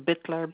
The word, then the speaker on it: Bitler: It's spelled with a JOLANTA, Bittler, BITLER.